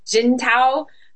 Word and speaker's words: Jintao: Jintao.